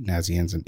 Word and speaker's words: Nazianzen: Did I say that right? Nazianzen. Yes.